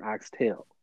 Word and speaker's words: oxtail: And I'm not going to say, and mainly for the proper pronunciation, oxtail. Like, there is no S. There's an X, but there's no S.